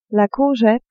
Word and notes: courgette: female

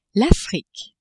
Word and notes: Afrique: French

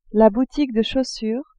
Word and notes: boutique de chaussures: female

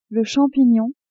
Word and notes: champignon: female

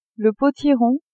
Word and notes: potiron: female

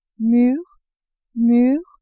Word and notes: mur mure: French

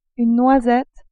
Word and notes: noisette: French

